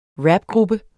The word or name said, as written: rapgruppe